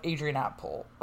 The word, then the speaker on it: Adrianople: So basically, you have in 378 the Battle of Adrianople, that's right, that's how you say it.